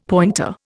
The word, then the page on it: pointer: Indian